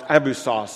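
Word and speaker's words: abyssos (ἄβυσσος): It would just be pronounced abyssos, spelled a little different in the original.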